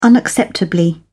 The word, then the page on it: unacceptably: British English